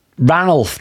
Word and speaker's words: Ranulf: We will replace Sir Randolph Fiennes Ranulf, not Randolph. – Ranulf. I said Ranulf. – Did you? – I don't think I pronounced the D then.